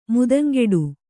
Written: ಮಾತುಂಗುಟ್ಟು

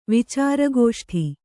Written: ವಿಚಾರಗೋಷ್ಠಿ

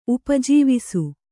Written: ಉಪಜೀವಿಸು

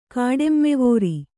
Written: ಕಾಡೆಮ್ಮೆವೋರಿ

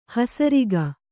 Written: ಹಸರಿಕೆ

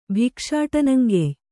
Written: ಭಿಕ್ಷಾಟನಂಗೆಯ್

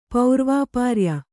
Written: ಪೌರ್ವಾಪಾರ್ಯ

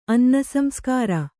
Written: ಅನ್ನಸಂಸ್ಕಾರ

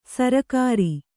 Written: ಸರ್ಕಾರಿ

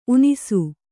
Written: ಊನೈಸು

